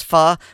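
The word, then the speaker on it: phở: Although it's probably pronounced philosopher, because the Vietnamese dish pho with the little over the o is more properly pronounced pho.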